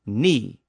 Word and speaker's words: knee: That includes words like need, K-N-E-A-D, and knee, K-N-E-E, and kneel, K-N-E-E-L, know, K-N-O-W, knife, K-N-I-F-E, knit, K-N-I-T, and knock, K-N-O-C-K. All of those words go back to Old English, and they were all once pronounced with an initial K sound.